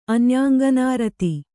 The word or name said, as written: ಅನ್ಯಾಂಗನಾರತಿ